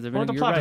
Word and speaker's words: Puerto Plata: Is that how you say it? – I'm looking at it. I don't have my glasses on here. – Puerto Plata. – Puerto Plata. – Right. – Thank you. I am correct.